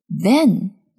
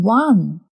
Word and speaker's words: van: Van 厢式货车，这种车，嗯，我们也叫它面包车。这种车是全封闭的，van。注意发音，不要说成 one。